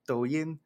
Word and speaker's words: Douyin: Douyin.